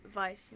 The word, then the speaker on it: Weiss: It's Weiss, but Weiss in German.